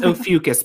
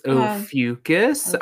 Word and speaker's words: Ophiuchus: It's Ophiuchus, by the way. – Thank you. I was like, you just told me how to say this and I can't remember. – Yep. It's Ophiuchus. I don't know if it's right. It's just how I heard it and no one's correcting me.